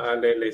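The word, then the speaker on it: Faalele: a Daniel Falele, creo que se pronuncia. – Falele, sí.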